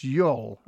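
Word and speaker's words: jól: Which is comes from, now this is J-O-L, so it's probably pronounced Yol, I would imagine, with a soft J.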